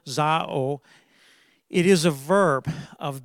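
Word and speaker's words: Zao (ζάω): So yeah, this Greek spelling Z A O pronounced Zao it is a verb to live, to breathe, uh, to have life.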